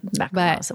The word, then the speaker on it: macaron: Macaron. – Well, it's not two O's. A macaroon. – Oh, would be two. – Is the coconutty thing. Macaron is the French little thing.